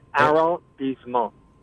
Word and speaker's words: arrondissement: Like Aaron, the name, like the name Aaron. Arrondissement.